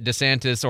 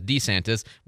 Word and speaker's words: DeSantis: I have no doubt that DeSantis, or as he says, DeSantis, why does he mispronounce his own name? It's so weird. You know, why can't he say his own name right? – Ask Ray Walker. – I know. Anyway, I can't bring myself to say DeSantis. I just can't. I know that's the right pronunciation. I can't bring myself to say it.